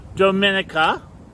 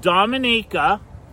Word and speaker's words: Dominica: So we're going to talk about Haiti, we're going to talk about Dominica, I keep saying it wrong, I don't know why, and I've been there so many times. We're going to talk about Dominica, which is different than the Dominican Republic.